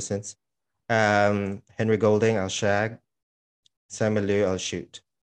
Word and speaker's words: Simu Liu: Simu Liu? Is that how you pronounce it? – Simu Liu. Yeah.